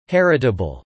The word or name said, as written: heritable